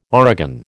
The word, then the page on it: origan: American English